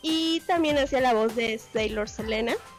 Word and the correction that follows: Serena: No sé si lo pronuncié bien. Entonces, Selena, Serena. Serena, lo siento, Serena.